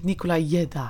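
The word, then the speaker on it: Nicolai Gedda: Ja, Roland, ja, ja, hij schijnt Zweeds te zijn of Scandinavisch. – Ja. – Dus dan is het Nicolai Jedda.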